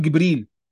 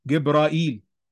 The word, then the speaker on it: جبريل: هتجد مجموعة من القبائل بتقول جبريل، اسمع كده في العراق هتلاقي جبرائيل،